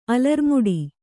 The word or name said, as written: ಅಲರ್ಮುಡಿ